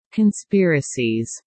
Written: conspiracies

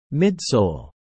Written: midsole

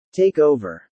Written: take over

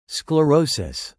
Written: sclerosis

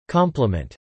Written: compliment

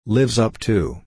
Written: lives up to

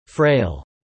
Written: frail

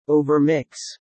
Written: overmix

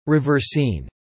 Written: reversine

